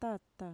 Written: tata

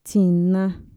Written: txina

